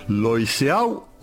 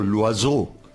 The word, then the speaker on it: Loiseau: Me estoy refiriendo a Juan Matías Loiseau o Loiseau, según quien lo pronuncie, Lucía.